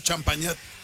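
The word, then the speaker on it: Champagnat: Salud para Renatita, que va bien pilas al Potosino, lista para la Copa Champagne. – Champañat. – Así se llama. – Se pronuncia, es Copa Champañat.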